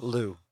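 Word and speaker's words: Lugh: Lou. – Lou. Okay. – Yeah. The G is silent.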